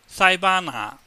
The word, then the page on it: saibaanngah: Cantonese